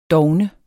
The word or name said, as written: dovne